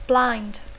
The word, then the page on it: blind: English